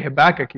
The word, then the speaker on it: Habakkuk: In fact, we can't even agree on how to pronounce the word Habakkuk. Some of you, when you heard me say Habakkuk, you said Habakkuk. Is it Habakkuk or Habakkuk? It's either way is fine. You can say it either way you want, and it's totally appropriate.